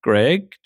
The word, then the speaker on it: Greg: It's Dr. Dr. Greg, G-R-E-G. And Schruer is my surname, S-C-H-R-E-U-W-E-R.